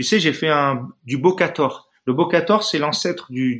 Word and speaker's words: Bokator: Comment, tu l'écris B-O Bokator, comme ça se prononce.